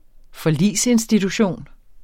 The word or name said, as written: forligsinstitution